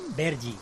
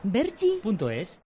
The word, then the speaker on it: Vergy: Vergi. – ¿Cómo se escribe? – V-E-R-G-Y. – Vergi.es.